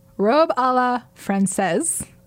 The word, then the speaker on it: robe à la française: Robe à la française. – And it's not Francois? – I have said it all the wrong way. – You said it right.